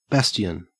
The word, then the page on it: bastion: Australian English